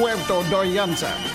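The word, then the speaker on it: Puerto Vallarta: De nieuwe binnenkomer Jack de Nijs, ook wel bekend onder de naam Jack Jersey en Puerto Dayanta. Ja, twee L's spreek je uit als een J. Volgens mij zong je het ook zo, hè? Puerto Dayanta.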